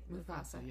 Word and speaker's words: Mufasa: Am I saying his name right? – Mufasa, yes.